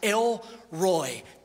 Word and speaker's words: El Roi: She gave this name to the Lord who spoke to her, and that name is El Roy. E-L-R-O-I. It's pronounced El Roy.